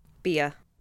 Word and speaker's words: Bia: And she and I don't know if she pronounces it Bia or B-I-A. – Bia. – Bia. Okay. Because it's short for Bianca, right?